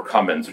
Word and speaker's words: Comyns: Well, it's written by this British writer named Barbara Cummins, which is spelled Comyns,